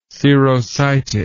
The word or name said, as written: serositis